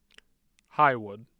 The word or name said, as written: Highwood